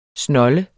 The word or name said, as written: snold